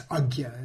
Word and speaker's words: Ajna: Sometimes people say Ajna or Ajna. It's Ajna in the Sanskrit pronunciation.